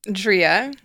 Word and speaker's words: Dria: Okay, so Alexandra, how would you like me to say your name? Alex, I call you Alex. Is it Alexandria? Alexandra? – Dria.